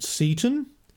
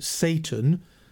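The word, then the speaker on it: Seyton: Over the years, commentators on the play have suggested that the third murderer is Macbeth's servant Seaton. By the way, I prefer that pronunciation of his name rather than Satan, as I don't like to set up a kind of verbal echo of Lucifer.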